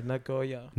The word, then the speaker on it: Nagoya: When you have O and Y next to each other in Hiragana, you don't put them together like boy. It's O and then the next syllable. – Yeah, Nagoya.